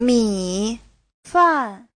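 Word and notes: 米饭: mi3fan4

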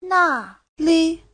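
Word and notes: 那里: na4li5

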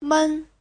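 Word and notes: 们: men5

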